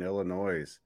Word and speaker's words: Illinois: Illinois. – Isn't that amazing? First off, it's pronounced Illinois. They don't say the S, but welcome other people, especially from out of state, to pronounce it Illinois, which is just hilarious.